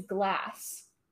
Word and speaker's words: glass: It's definitely spelled that way, and from what I understand, it's pronounced that way as well, because A is pronounced ah. – Yeah. – So, glass. – Gloss.